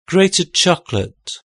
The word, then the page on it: grated chocolate: British English